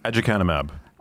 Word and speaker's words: aducanumab: We're going to leave now, if you're watching us on YouTube, to talk about, can I make sure, how do I say that drug again? – Aducanumab. – aducanumab. – Yeah.